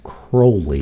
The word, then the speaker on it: Crowley: Alistair would have told you, my name is Crowley. – That's what I've heard. – It rhymes with holy.